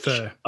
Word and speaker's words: pho: pho is fur. It's P-H-O. pronounced fur.